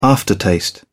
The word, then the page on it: aftertaste: British English